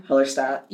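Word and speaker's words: Hellerstedt: Believe it was the Whole Woman versus Hellerstadt.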